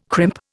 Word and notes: crimp: Indian

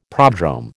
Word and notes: prodrome: American English

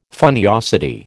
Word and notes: funniosity: American English

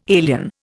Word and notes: alien: Indian

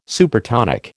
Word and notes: Supertonic: American English

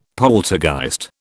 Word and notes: poltergeist: British English

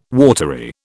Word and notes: watery: British English

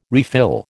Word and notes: refill: American English

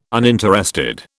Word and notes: uninterested: British English

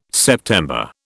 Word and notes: September: British English

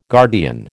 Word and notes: guardian: American English

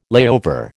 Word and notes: layover: American English

